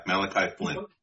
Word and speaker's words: Malachi Flynn: The Knicks received Anunoby, Malachi Flynn. Is that how you pronounce it? – Malachi Flynn.